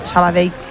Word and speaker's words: Sabadell: El cachondo de Kiki Lesa me dice, pronuncias muy bien Sabadell. Dice, mis respetos. Sabadell. – A mí me lo decían mucho cuando estaba en Primera Federación, porque yo también decía Sabadell, que es como se dice.